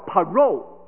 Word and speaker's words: parole: 但是他们有另外一个字叫做 parole 我的孩子一定说我发音不准确的了，不要紧了，法国哎，不要理他了哈。这个 parole 或者叫 parole P-A-R-O-L-E parole，它的意思是什么？